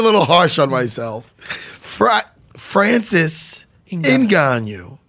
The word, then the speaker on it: Ngannou: Did I say Ngannou right? – No. – Good. How do you say it? – Francis Ngannou. – Ngannou. You know why I said Ngannou? Because the N is silent.